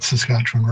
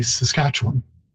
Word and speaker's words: Saskatchewan: And but it is interesting that I had already mentioned earlier that people inside the province say Saskatchewan, and outside you hear that Saskatchewan. It's actually because we know how to pronounce it. We've heard it all along since it was borrowed and have kept closer to the Cree pronunciation than outside, where people see the spelling W-A-N and so mispronounce it as Juan.